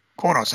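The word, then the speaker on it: Qo'noS: Oh, Kwonos. Kwonos, that's how it's pronounced.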